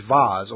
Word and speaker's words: Vase: The Challenge Vaz, or Vase, depending on how you like to pronounce that word, is an annual football competition for teams playing below Step 4 of the English National League system.